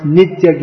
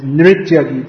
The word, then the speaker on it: Nritya: Sometimes that's pronounced Nitya Gita. Srila Prabhupada himself often pronounced it like that because that's the Bengali way of pronouncing it. But it's Nritya Gita.